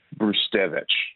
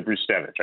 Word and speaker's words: Brzustewicz: in Hunter Brustet. I'm going to mess with this name a lot. I literally had the name of the defenseman right in front of my face until the pronunciation guide. Brustevich. – Yes. – I got it. Hunter Brustevich. I got it right.